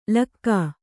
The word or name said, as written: ಲಕ್ಕ